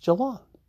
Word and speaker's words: Geelong: So uh, I, I said to John in a tweet, I don't want to be that guy, but just so you know, the town Liam Spence is from is pronounced Geelong.